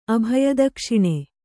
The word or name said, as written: ಅಭಯದಕ್ಷಿಣೆ